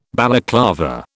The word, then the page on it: Balaclava: British English